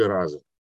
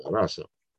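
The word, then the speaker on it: rasa: Раса или раза. Как она? Раса.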